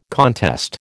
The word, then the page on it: contest: American English